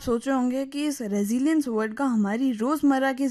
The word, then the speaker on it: resilience: इसकी जो स्पेलिंग है वो है RESILIENCE, और इसको बोलते हैं रेजिलियंस।